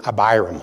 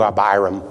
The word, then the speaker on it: Abiram: Probably I like Cliff's pronunciation, Abiram. I like that. So we'll do Abiram.